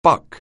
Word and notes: buck: anglais

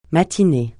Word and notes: matinée: francais

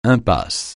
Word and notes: impasse: francais

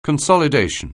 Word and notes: consolidation: anglais

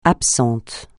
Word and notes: absent: francais

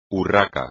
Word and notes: urraca: espagnol

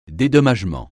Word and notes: dédommagement: French

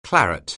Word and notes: claret: anglais

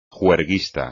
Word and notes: juerguista: Spanish

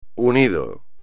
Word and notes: UNIDO: espagnol

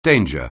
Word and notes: danger: anglais

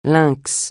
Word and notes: lynx: francais

